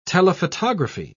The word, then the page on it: telephotography: British English